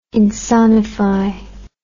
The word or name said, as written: insonify